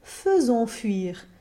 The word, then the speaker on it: faisons: So in the third strophe, it isn't faisons or faisons fuir, but rather faisons fuir, with a very clear schwa vowel in that first syllable.